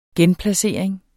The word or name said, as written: genplacering